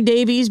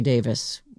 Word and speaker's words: Davies: You know, I I try to say Davies because that is the American pronunciation, but I believe that they say Davis when when it's spelled Davies.